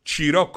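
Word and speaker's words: Cirò: ci incontriamo, io sono a Ciron Marina. Ciron Marina. Ciron, ma aspetta, come cazzo? Ciron, ma che lingua è? Ciron? No, Ciro, Ciro, Ciro con l'accento.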